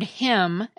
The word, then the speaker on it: hymn: The word hymn, as in a hymn that you sing in church, has a silent N at the end of it.